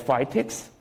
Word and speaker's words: FCITX: So if you see this FCITX is pronounced as FITX, and the IBUS, these two are input method, so you need this to input things.